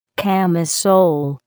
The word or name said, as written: camisole